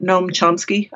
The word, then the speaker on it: Noam Chomsky: And I actually just listened to your interview with Gene Epstein, where he was talking about Noam Chomsky.